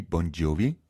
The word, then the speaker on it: Bongiovi: Es ist ein Wortspiel, eigentlich schreibt man, eigentlich ist Bon Jovi ein Wort, also B-O-N-G-I-O-V-I, Bon Jovi.